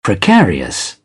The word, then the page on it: precarious: British English